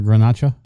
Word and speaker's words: Garnacha: Garnach or Garnacha? – Garnacha.